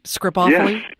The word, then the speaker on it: scripophily: Scripophily? – Yes, scripophily.